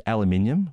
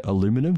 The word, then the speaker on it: aluminium: As a side note, Jerry, I just want to point out that I said aluminium, which might be jarring for some of our listeners because I think they say aluminum.